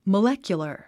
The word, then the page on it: molecular: məlékjulə r; モレキュラァ